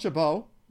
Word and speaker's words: Chabot: And you know, they have Thomas Chabot, as I've been informed that it's pronounced.